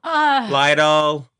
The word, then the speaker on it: Lytle: It's spelled L-Y-T-L-E. I don't know how to pronounce it. Can you send in Lytle Adams? – Yes, Mr. Roosevelt. Lytle. Lytle. Come on in. – It's Lytle. It's French.